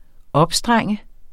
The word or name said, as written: opstrenge